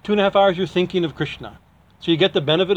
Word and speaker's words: Krishna: He says because the it's not Krishna, it's Krishna. You know, the syllables have to be that.